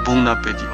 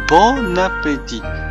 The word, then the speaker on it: bon appétit: 我们可以简单的说 bon appétit 啊，注意这边发音不要发成 bon appétit，我们只是 bon appétit，或者更简单说 bon app，OK？